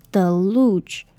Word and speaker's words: deluge: 首先，第一个字 deluge，这里的 e 是发 e 的音。然后这里的 U E 是发 U 的音，然后不知道你们记不记得，但是如果当 G 的后面是 E 的时候，那个 G 要念成 J，就是有点类似 J 的音这样，所以它叫做 deluge。